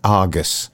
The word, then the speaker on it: Argus: The Brighton Argos. – Well, people are going to think that bit's the way you can go on by. – Sorry, I've mispronounced it. The Brighton Argus.